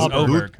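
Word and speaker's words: Åberg: Yes, Oberg. – Ludwig Oberg. – Oberg. Not Auberg. – It's pronounced Oberg? – Yeah. Oberg. I know it's an A with a little thing over the top of it.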